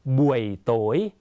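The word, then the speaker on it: buổi tối: ตอนค่ำออกเสียงว่าบุ่ยโตย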